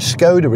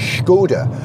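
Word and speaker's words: Škoda: Everyone says Skoda, but it's not. If you look at the logo, it's got a little, is it called the circumflex? It's got a little accent over the S, which means that you have to pronounce the S as a sh, so it's Skoda. You've got to imagine that you're Sean Connery, it's a Skoda.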